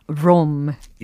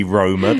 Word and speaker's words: Roma: 근데 로마를 영어로는 Rome. – Yeah, we ruin the pronunciation. – 네. It should be Roma.